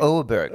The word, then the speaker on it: Åberg: Åberg.